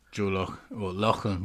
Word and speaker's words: Loughlin: And Republic of Ireland rider Joe Loughlin. Is that how you say it? – Joe Loughlin. Yeah, that sounds about right.